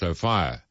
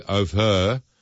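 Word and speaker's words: Ophir: Luke, the O'Fire in Hotel in Orange is pronounced O'Fire. Some people say O'Fur. So while the first is proper, the second can be acceptable.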